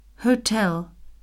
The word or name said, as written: hotel